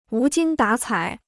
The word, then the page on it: 无精打采: wú jīng dǎ cǎi